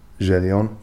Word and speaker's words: Gideon: But the pronunciation Gideon, it's French, like pretty much. So the name is just Gideon, but in French, that's how you say it, Gideon.